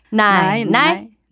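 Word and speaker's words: 나이나이 (奶奶): 라이라이 발음은맞으셨는데성조가자삼성경성이니까 나이나이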